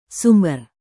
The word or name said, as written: sumber